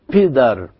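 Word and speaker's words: پدر: يعني عندنا مثال كلمة الأب في الفارسية هي كلمة بدر، بدر باء تحتها 3 نقطات وبعدين دال وراء، فتتنطق بيدر بيدر، يعني بدل ما يقول بدر كأن الباء عربية بتتنطق بيدر، الباء ثقيلة أو الباء الفارسية المثلثة.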